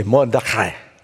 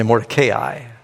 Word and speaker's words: Mordecai: If you're Jewish, you say Mordecai. You don't have to say it like that. And some people say Mordecai. I've said it like that. I've even heard, just yesterday, I heard a Baptist pastor saying it that way.